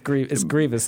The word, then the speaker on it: Grievous: It's Grievous. There's no I.